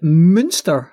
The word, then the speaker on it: Münster: So, the Münster Circle, I hope I'm pronouncing that correctly. – That sounds excellent.